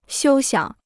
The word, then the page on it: 休想: xiū xiǎng